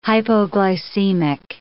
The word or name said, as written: hypoglycemic